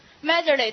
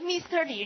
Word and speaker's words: miserly: 俘虏就够了，这说谁呢？Miserly，对不对？Mr. Lee 是不是？小气鬼，还记得吗？哈，吝啬鬼，小气鬼，哈。其实它读成什么呢？读成 miserly，但是咱们为了谐它的音好记，Mr. Lee 的感觉是不是？所以叫那个叫小气鬼，读成 miserly 哈。你不要读成 Mr. Lee，人家都不知道你说谁，是不是？